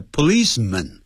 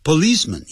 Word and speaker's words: policeman: The police 呢是警方，如果某个警察那叫 a policeman，Police 后头加个 M-A-N，那念的时候不要念成 policeman，叫 policeman，发的弱音啊。如果是很多警察 policeman 也是一样 P-O-L-I-C-E-M-E-N 也是念成 policeman 这样子。好， What had happened，我把事情的经过告诉了这些警方这些警察们。